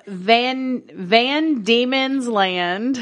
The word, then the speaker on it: Van Diemen's Land: Of Van Diemen's Land. I swear to fuck. – Oh my God. – Okay, it's three words. Van. – Yes. – Diemen's is spelled D-I-E-M-E-N apostrophe S. Land.